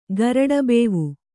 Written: ಗರಡಬೇವು

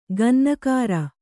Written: ಕನ್ನಾಗರು

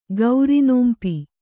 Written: ಗೌರಿನೋಂಪಿ

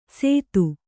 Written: ಸೇತು

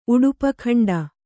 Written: ಉಡುಪಖಂಡ

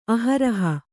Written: ಅಹರಹ

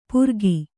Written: ಪುರಗಿ

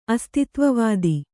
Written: ಅಸ್ತಿತ್ವವಾದ

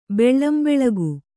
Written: ಬೆಳ್ಳಂಬೆಳಗು

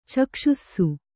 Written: ಚಕ್ಷುಸ್ಸು

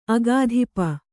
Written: ಅಗಾಧಿಪ

